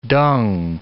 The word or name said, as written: dung